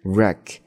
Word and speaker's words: wreck: 这个地方 wreck 这个单词前面的第一个 W 不发音， wreck 是撞的意思。